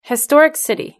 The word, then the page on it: historic city: British English